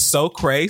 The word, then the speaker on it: Socreigh: It's funny because I didn't know how to say their name until just right in this moment. But So Cray, spelled SOCREIGH.